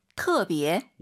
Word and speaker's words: 特别: 근데 발음하실 때 터 아니고요. 特어라고 보시면 됩니다. – 特别.